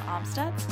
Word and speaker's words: Amstutz: And you can find me on all platforms at Sandra Amstutz.